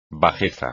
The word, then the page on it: bajeza: espagnol